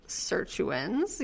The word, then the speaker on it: sirtuin: Am I saying that right? Sirtuin? – Yeah, sirtuins.